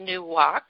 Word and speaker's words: nuwach: There's the Hebrew word for rest is pronounced nuwach, N-U-W-A-C-H, and the Aramaic word is called nak, N-A-K-H.